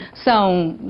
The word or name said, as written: sao